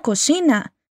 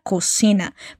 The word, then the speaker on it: cocina: ¿Percibiste que la chica dice «cocina»? Y no cocina. Esa es la tan famosa distinción que los españoles hacen al pronunciar la Z y la C-E-I-C-I, que sale como Z-C-I-C-I, que es un sonido diferente al de la S.